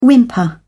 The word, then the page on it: whimper: British English